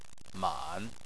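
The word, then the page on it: man: tone 3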